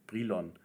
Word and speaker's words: Brilon: Das heißt Brilon und ich war mir nicht sicher, wie man es ausspricht. Ich habe es vielleicht Brilon oder Brilon ausgesprochen. Brilon, so heißt es richtig.